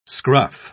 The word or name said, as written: scruff